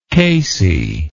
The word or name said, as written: casey